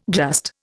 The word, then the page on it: just: Indian